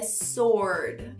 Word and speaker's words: sword: A gente encontra em sword, espada, não é sword, é sword.